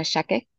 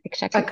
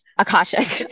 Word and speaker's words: Akashic: And so when I went back to Canada after those 3 weeks, I got you to do an Akashic, I always say it wrong, Akashic. – Akashic.